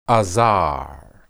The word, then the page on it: azar: hebrew